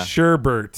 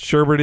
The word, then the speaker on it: sherbet: But my mom, my mom, uh, she she really hated hearing people call sherbet Sher- yeah. sherbert. Mm. Now, according to Merriam-Webster's website, both are acceptable pronunciations, even though sherbet is clearly spelled without an R at the in without a second R. But sherbet is expected.